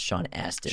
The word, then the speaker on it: Sean Astin: And I believe it's pronounced Sean Astin. – Sean Astin. – Not Austin, Thank Texas.